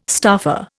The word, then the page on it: staffer: Indian